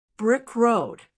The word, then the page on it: brick road: American English